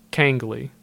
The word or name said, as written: Kangley